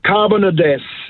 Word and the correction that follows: Carbonados: It is Carbonados.